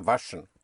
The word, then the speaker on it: waschen: Waschen. – So even if you say waschen, you will be understood. They will say, ah, waschen. So it's waschen. Because the W is pronounced like a V. Waschen.